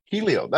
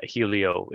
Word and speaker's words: Hylio: Yeah, it's spelled Hylio, so I don't blame you for thinking that. But yeah, Hylio, it's named for the Greek god of the sun, Helios, actually.